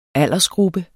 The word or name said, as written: aldersgruppe